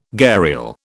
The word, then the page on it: gharial: British English